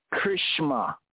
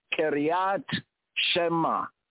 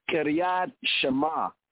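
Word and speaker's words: Kiryat Shema (קריאת שמע): In colloquialism, the two words are frequently run together, so the pronunciation is somewhat corrupted, and it sounds like Krishma, Krishma. So when they say it, Kriyas, Kriyas, Shema together, Krishma. But the proper pronunciation is Kiryat Shema, Kiryat Shema.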